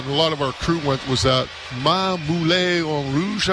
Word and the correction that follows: Ma Poule Mouillée: T-Mart, how do you pronounce it? – Ma Poule Mouillée.